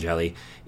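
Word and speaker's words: Hjelle: If you're not familiar with Sean Jelly, it's H-J-E-L-L-E. That's one of those I actually learned the pronunciation of, but he is 6'11".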